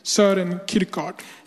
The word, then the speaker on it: Søren Kierkegaard: Han heter Søren Kierkegaard.